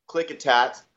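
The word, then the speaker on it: Klickitat: And it is pronounced Clickitat. Some of these counties are very difficult,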